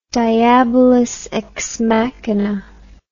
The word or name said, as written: diabolus ex machina